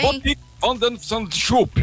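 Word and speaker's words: Botic van de Zandschulp: Olha a pronúncia holandesa. – Tá bem. – Botik van der Zandschulp. – O Schulp, só o Schulp.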